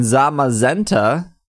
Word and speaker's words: Zamazenta: We have uh, actual pronunciations on this Zacian and Zamazenta uh,